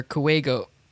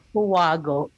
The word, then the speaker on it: kuwago: Kuwago or kuwego. Kuwago? – It would be like kuwago. – Yeah. That's kuwago. Yeah. – Because I think it sounds the way that it's written.